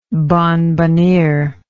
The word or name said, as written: bonbonniere